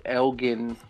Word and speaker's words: Elgin: and even though it is properly pronounced in Illinois, we call it Elgin here in Texas because we're Texas. – You're with a strong G. – Yes, the hard G for us.